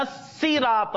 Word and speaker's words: صراط: إهدنا الصراط المستقيم. What we pronounce? إهدنا الصراط المستقيم. Remember, this is seen, not soul. إهدنا الصراط المستقيم. صراط الذين أنعمت عليهم.